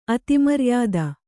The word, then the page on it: ಅತಿಮರ್ಯಾದ: Kannada